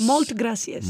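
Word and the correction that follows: Moltes gràcies: Moltes gracias.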